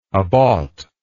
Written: abought

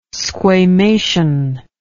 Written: squamation

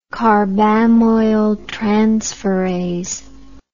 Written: carbamoyltransferase

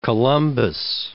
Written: Columbus

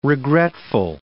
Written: regretful

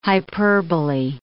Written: hyperbole

